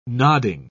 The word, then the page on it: nodding: British English